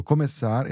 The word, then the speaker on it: começar: So começar is spelt with a C. – Yeah, the word for to start. – To start. So it's C-A-R. So you should really read it come car. – Mm-hmm. – But that's not the case because that final syllable is spelt with a C sigilia. So that's the letter C with a tiny squiggly line under it.